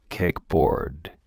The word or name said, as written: kickboard